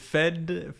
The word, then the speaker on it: Fede: It is Fede, F-E-D-E. – F-E-D-E. – In Spanish. – Yeah, Fede.